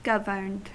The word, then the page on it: governed: American English